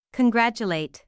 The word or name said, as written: congratulate